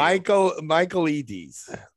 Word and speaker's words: Michaelides: Do you need me to pronounce it for you? – Michaelides. Alex Michaelides.